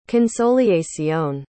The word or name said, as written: consolacion